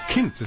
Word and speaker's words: Kind: From the great state of Wisconsin, it's Representative Ron Kind. – Well, or Kint, as they pronounce it in Germany.